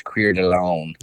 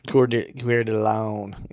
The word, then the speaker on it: Coeur d'Alene: Whenever I had him on, he mentioned, you know, well, he lives not far from, how do you say it? Coeur d'Alene. Coeur d'Alene. – Or if you're French, it's Coeur d'Alene. – Coeur d'Alene.